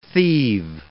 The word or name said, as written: thieve